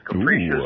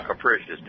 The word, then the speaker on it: capricious: There it is, capricious. – Ooh, or capricious. Take your pick on the pronunciation.